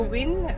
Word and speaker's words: Wien: So Wien, rhyming with the word Wien, W-I-N.